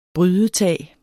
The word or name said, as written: brydetag